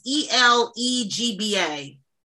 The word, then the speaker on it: Elegba: Ellie Gaba, Ellie, how do you say his name? It's E L E G B A. Elegba.